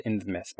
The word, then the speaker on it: Innsmouth: The town's called Innsmouth, by the way.